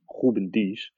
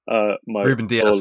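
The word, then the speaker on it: Rúben Dias: The guy to accompany Cancelo is Ruben Dias. – Ruben Dias for the non-Portuguese speaking.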